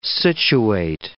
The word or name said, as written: situate